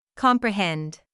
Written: comprehend

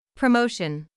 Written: promotion

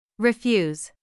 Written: refuse

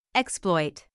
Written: exploit

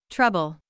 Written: trouble

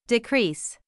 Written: decrease

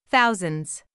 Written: thousands

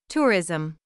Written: tourism

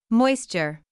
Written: moisture

